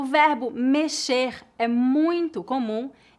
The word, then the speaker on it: mexer: Nesta lição, você vai aprender como usar o verbo mexer em oito situações diferentes. Vamos começar falando sobre a pronúncia do verbo mexer. A letra X nesta palavra é pronunciada como as letras SH em inglês.